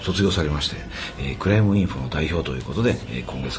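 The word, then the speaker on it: Crime Info: まあ、クライムインフォってアルファベットでね、クライム、 CRIME、まあ、犯罪のクライムにその後インフォ、インフォメーションの情報のインフォということで INFO と書いてクライムインフォっていうんですけども、はい、これはもともと過去 2 年間、欧州委員会という EU の機関から助成金を受けてやってきたプロジェクトなんですね。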